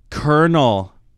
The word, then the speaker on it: Colonel: Colonel. Think about the way it's spelled. C-O-N-O-E. Did I spell it right? – No, it's whatever. – C-O-L-O-N-E-L. – It's not R's in there.